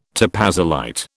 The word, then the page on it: topazolite: British English